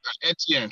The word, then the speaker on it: Etienne: I mean, I can't pronounce – is it Edney? I can't pronounce his name. – Etienne, Travis Etienne.